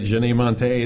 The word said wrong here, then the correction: Janelle Monáe: You mean Janelle Monáe? – That's it.